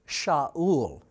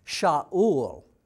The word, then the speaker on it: Shaul: Saul in Hebrew is pronounced Shaul. Shaul is what it's pronounced as.